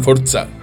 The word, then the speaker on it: Forza: Como é que fala em italiano? – Forza.